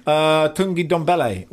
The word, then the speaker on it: Tanguy Ndombele: Look, they've broken their transfer record to sign Tanguy Ndombele, which I've pronounced about 10 different ways, but I believe that is the correct way.